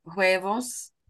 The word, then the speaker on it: huevo: Entonces, entonces la H en español no se pronuncia. Entonces no es huevos, pero huevos. – Huevos. Oh, okay. Huevos. La H es silente.